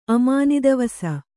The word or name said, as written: ಅಮಾನಿದವಸ